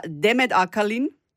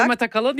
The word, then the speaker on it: Demet Akalın: Und zwar Demet Akalin. – Ja. – Habe ich das so richtig gesagt? – Ja, Demet Akalın, ja, super.